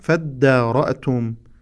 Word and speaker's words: فادارأتم: وإذ قتلتم نفسا فادارأتم فيها، لاحظوا إخواني وأخواتي، وإذ قتلتم نفسا فادارأتم فيها، هذه الكلمة كيف تقرأ؟ فادارأتم، يعني ألف الوصل لا تلفظ ولا ننطق بهذه الألف.